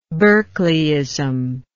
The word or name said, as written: berkeleyism